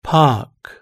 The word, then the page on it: park: British English